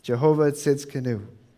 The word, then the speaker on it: Jehovah Tsidkenu: The joke around the office all week was that it's Jehovah sits in a canoe. That's more or less how it sounds.